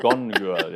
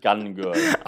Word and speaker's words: Gone Girl: Gone Girl, ja, nicht Gun Girl.